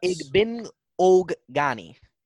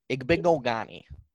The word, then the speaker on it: Igbinoghene: It's pronounced Igbinoghene. Igbinoghene.